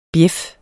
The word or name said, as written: bjæf